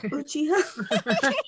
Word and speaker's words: Uchiha: Uchiha. – It's a hard CH.